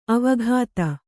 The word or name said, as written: ಅವಗತಿ